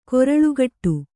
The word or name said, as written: ಕೊರಳುಗಟ್ಟು